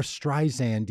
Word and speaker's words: Streisand: like how would they say Barbara Streisand? I hadn't been pronouncing the D. I think in my mind, I was spelling Streisand with no D.